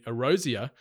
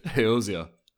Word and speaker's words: Eorzea: The live action television series will tell an original story set in Erosia, the world where Final Fantasy XIV is set. – That was that was awful. How do you pronounce it? – Eorzea.